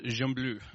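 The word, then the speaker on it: Gembloux: It's a small, nameless community in a town called Jambleu, if you want to pronounce it correctly in French.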